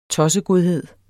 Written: tossegodhed

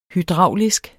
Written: hydraulisk

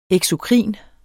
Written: eksokrin

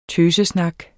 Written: tøsesnak

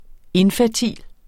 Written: infertil

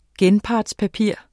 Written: genpartspapir

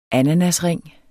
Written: ananasring

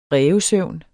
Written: rævesøvn

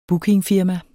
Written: bookingfirma

